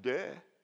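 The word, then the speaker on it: de (δέ): In Greek, it's pronounced de. D-E-H, de.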